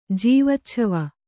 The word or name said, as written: ಜೀವಚ್ಛವ